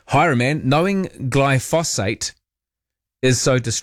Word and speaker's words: glyphosate: And you are adding an extra syllable. It's glyphosate.